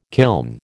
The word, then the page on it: kiln: American English